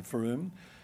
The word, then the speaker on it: Frome: Frome spelled F-R-O-M-E, but if you want to look it up, pronounce Frome.